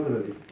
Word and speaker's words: гроб: Дарин, по гробе. Ага. Ангел. – Мироносица. – Ага. – Вещаше. – Вещаше. – Видите в этот на конце там ер, вот не читается, это твердый знак.